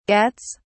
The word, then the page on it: gets: British English